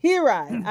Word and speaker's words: Hirai: Pronounce your last name. – Hirai. – I got both syllables wrong.